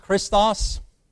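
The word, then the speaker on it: Christos (Χριστός): Christ, the lexicon pronunciation in Greek, is Christos.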